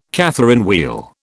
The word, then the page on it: Catherine wheel: British English